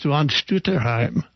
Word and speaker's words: von Stutterheim: But it's von Stutterheim. – Von Stutterheim. – Stutterheim. You can't do it too Germanically, or it sounds like one of those anti-German movies from World War II, you know.